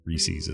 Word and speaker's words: Reese's: Here in the Western states, we always said Reese's, but I think you're actually saying it right. Reese's is the right way to say it.